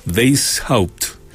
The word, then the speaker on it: Weishaupt: Ricardo Weishaupt, parece que se pronuncia, Weishaupt, asumirá como gerente general de la división Chuquicamata.